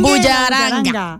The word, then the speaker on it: bullaranga: Hoy hemos querido pues escuchar mucha música, estar relajaditos, porque es que se nos viene la bullarenga, ¿sí o no, machitas? – La bullarenga. – Merenga, bullaranga, ¿cómo se dice? – Bullaranga. – ¿Bullaranga? – Bullaranga.